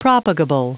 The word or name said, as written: propagable